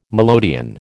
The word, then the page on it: melodeon: American English